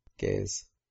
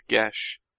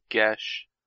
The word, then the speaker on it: geas: Gaze. – No, it's Gesh. – What word? – The the word that you said. – G E A S is Gesh.